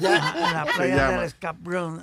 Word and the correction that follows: Escambrón: No, no es cabrón. Oh, ok, you see, el hecho que tú pongas acento en inglés no está corrigiendo la vaina. Escambrón. – No escabrón. Ok.